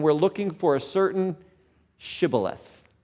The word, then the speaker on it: shibboleth: They would say shibboleth. And all the Ephraimites pronounced it Sibboleth, and they could immediately tell they were Ephraimites.